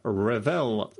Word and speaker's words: Revel: Thank you very much to Matt Ewers, Elizabeth Yunkin, Britta Rogowski, Granny McGuire, Matthew Toy, Christopher Revel, rhymes with spell, Steve E., Andrew Potts, Phil Holland, A.V.